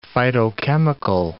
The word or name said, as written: phytochemical